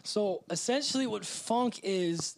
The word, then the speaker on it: phonk: So it's called funk, but it's spelled P-H-O-N-K.